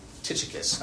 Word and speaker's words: Tychicus: Paul talks about Tychicus. That's how I pronounce his name.